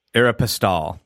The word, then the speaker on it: Aeropostale: So this first one back in 2016, Simon got together with Brookfield and they said, hey, together we have most of the Aerospall, I always say it wrong, do you? Aeropostale, sorry.